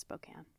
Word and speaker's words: Spokane: And of course, Spokane spelled like Spokane. You'd think it would be Spokane, but it's Spokane.